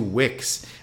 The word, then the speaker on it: Wieck: But, and it also gives me a chance to mention yesterday when Brad Wick, the Cubs now have 2 Wicks. – Is that how you pronounce his name too? – It's Wick. – I know it's spelled, it's spelled W-I-E-C-K. Yes. So it's Wick as well? – Yeah, okay.